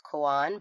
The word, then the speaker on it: koan: And we keep thinking that it's pronounced cone. I've also heard it pronounced koan, but I'm pretty sure it's cone. It's K-O-A-N, in case you're curious.